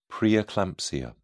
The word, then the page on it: preeclampsia: British English